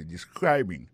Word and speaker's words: describing: Alors, describing là c'est participe présent verbe to describe ça nous ka wè là ça nous constater là nous ka constater que il y a prononcé mot de describing accent tombé sous deuxième syllabe là ça veut dire mot ça a trois syllabes describing so,